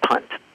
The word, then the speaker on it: Punt: It's P-U-N-T. Is it punt? – Punt, yes.